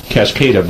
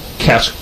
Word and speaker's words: Cascada: Boy, they make you stumble all over it, but it's actually spelled C-A-S-C-A-D-A, which you would think it's Cascada, but it's not. It's actually kind of a hard K in the middle, so it's Cascada.